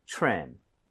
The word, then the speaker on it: trim: TRE 붙어 있어요. TRE 붙어 있고 그다음에 모음으로 연결이 되면 크리스마스 트리처럼 치읓 비슷해집니다. – 그쵸. – 그래서 입 모양을 모으고 발음해 주세요. 가보겠습니다. Trim, trim.